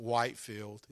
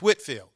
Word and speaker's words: Whitfield: You know, I shared the story recently about the George Whitfield, who down in Savannah, Whitfield Avenue, my GPS always mispronounces it Whitefield. It's Whitfield, George Whitfield, evangelist who would open air preach in front of thousands and thousands of people.